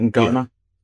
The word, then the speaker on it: Ngoma: Like, the pronounce his name, Rio Ngoma, is it Ngoma?